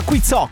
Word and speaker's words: Quizzo: Allora, stiamo giocando, si chiama Quizzo, con l'accento sulla O.